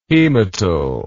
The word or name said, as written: hematal